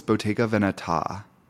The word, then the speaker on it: Bottega Veneta: So I was like, it's Bottega Veneta, right? Just to make sure right off the bat. I didn't want to be like walking around the store saying it incorrectly. She goes, it's Bottega Veneta. Okay, Bottega Veneta.